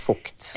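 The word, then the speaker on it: Vogt: Uttales, det skrives Vågt, men det uttales Fukt.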